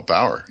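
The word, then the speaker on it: Baur: Checking out the competition, and Wolfgang Bohr. – Oh, Bauer, yeah. – Bauer, okay.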